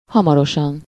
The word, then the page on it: soon: /suːn/